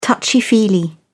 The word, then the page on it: touchy-feely: British English